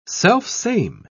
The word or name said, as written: selfsame